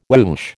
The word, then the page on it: whoosh: American English